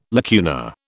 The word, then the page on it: lacuna: British English